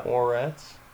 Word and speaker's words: Moretz: Is that how you say it? – Yeah. – Moretz.